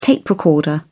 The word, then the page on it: tape recorder: British English